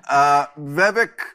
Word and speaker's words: Vivek: Just to get the idea, this person, foreigner, funny name, Vivek, is it? – Vivek.